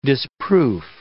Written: disproof

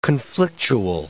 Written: conflictual

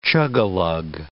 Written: chugalug

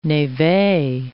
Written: neve

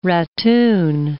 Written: ratoon

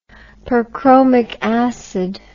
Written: perchromic acid

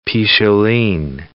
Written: picholine

